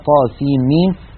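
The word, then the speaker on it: طسم: طاسين ميم، وتلفظ هكذا طاسين ميم.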